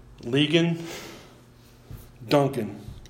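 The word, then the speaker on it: Ligon Duncan: In the film, The God Who Speaks, the chancellor of the Reformed Theological Seminary, I believe his name is pronounced Legan Duncan,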